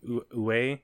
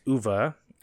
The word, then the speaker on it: Uwe: I was saying Uwe. It's Uwe. As clarified in the film Postal, which he had a cameo in.